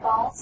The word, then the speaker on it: Baals: Is that correct, Baals? – Baals. – Baals. – Yeah, two A's. – Baals. – And actually there's a letter between the A's, but it's unpronounceable.